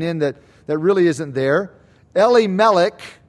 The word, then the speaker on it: Elimelech: Verse 2, we read, the name of the man was Elimelech, or more a Hebrew pronunciation, Elimelech.